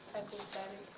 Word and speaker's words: hypostatic: Can you pronounce that? – Hypostatic. – Very good.